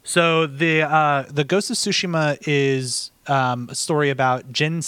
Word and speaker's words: Tsushima: It's not Ghost of Tsushima. You put the wrong emphasis on the wrong syllable when you say it that way. It's Ghost of Tsushima.